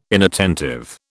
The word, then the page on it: inattentive: British English